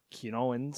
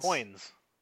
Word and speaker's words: Qoins: It's the Q-O-I-N-S. Quinoins. – Coins. – Coins? Oh, well, I guess that makes sense.